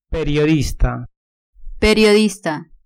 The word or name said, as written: periodista